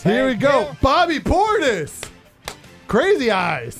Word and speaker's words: Portis: It's pronounced Portis.